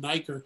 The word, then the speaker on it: Nieker: You can just Google my name, David Nyker. You got to spell it right, N-I-E-K-E-R.